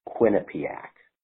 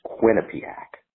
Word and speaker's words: Quinnipiac: I just want to let you know, as a native of Connecticut, proper pronunciation is Quinnipiac. They've got good college hockey as well, and you sometimes hear them mentioned in media polling because they do that. But it's Quinnipiac. There's only one pronunciation.